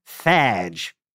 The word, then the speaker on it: fadge: You know, it's interesting because etymologists now think that fudge came from an older obsolete term, fadge, which sounds like fudge with a weird New England accent. – Yeah. – F-A-D-G, right? – Exactly. F-A-D-G-E with the silent E, which I still don't understand.